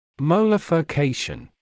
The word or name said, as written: molar furcation